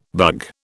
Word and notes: bug: British English

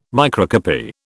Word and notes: microcopy: British English

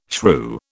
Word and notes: shrew: British English

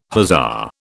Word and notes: hussar: British English

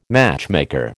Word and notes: matchmaker: American English

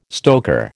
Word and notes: stoker: American English

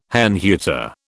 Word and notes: Herrnhuter: British English

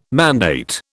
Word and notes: mandate: British English

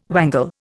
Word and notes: wangle: Indian

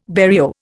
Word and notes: barrio: Indian

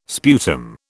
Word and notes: sputum: British English

